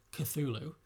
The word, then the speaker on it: Cthulhu: Cthulhu, thank you.